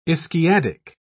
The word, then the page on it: ischiadic: British English